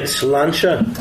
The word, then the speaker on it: Sláinte: Sláinte.